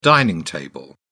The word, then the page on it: dining table: British English